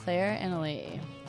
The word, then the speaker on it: Claire Annalee: My Instagram is Claire Annalie. C-L-A-I-R-E. A-N-N-A-L-E-E.